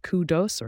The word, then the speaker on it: kudos: K-U-D-O-S, pronounced kudos or kudos, depending on your preference, and it's a noun.